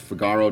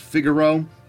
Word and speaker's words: Figaro: Yes, we pronounce it Figaro, not Figaro. I've heard it pronounced that way. We're not going to do that. But it's fine if you do.